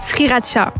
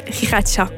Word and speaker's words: sriracha: Et donc, cette sauce-là, c'était la sauce sriracha. – Sriracha, oui. – Mais j'ai eu le malheur de mal prononcer la sauce. Et donc, à la base, j'appelais ça la sauce riracha.